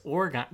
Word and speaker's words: Oregon: It's Oregon, the Oregon Trail, not the Oregon Trail.